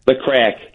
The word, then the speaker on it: Craic: Now, the Craic, is that how you say it? – The Craic. – Craic, yeah. Radio show.